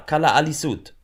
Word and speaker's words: Kalaallisut: Ah, Kalaallisut.